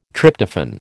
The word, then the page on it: Tryptophan: American English